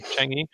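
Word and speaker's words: Chang'e: Is it Changi? – Cotton. – What? – God damn it. Not cotton. No, the probe. It's like Chang comma E. Changi.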